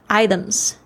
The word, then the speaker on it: items: 这边要特别注意一下哦，美式发音呢，这个 t 呢，如果是在两个元音之间呢，它会有浊化的现象，所以就听起来比较像是 d 的声音。 items，你等下可以注意听一下它是怎么样发这个声音的。